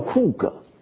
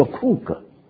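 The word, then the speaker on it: 库 格 (חוּג): 大圆圈这个字希伯来文发音叫库格，叫库格。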